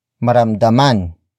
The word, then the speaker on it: maramdaman: Maramdaman is spelled as M A R A M D A M A N.